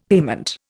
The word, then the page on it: payment: Indian